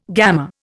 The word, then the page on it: gammer: Indian